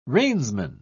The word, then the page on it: reinsman: British English